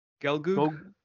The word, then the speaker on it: Gelgoog: Yeah, the battle between the Gul, what's, how do you pronounce it? – Gelgoog. – The Gelgoog and the Gundam is a pretty awesome battle.